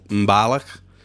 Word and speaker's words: Mbalax: Isso porque ele ajudou a desenvolver um gênero musical ali no Senegal chamado de Mbalak, mais uma vez vocês me desculpem a pronúncia, né? Se escreve M-B-A-L-A-X, só que se pronuncia algo do tipo Mbalach